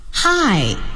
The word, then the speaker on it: High: 这三个字母在一起呢应该读作 I 好我们来举例子 I High 高高的 Highway 公路 I Night 是夜晚的意思有一个短语叫 From morning till night 从早到晚第三个 L I Light light 是光线的意思。